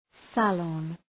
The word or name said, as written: salon